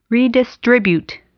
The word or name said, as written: redistribute